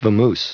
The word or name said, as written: vamoose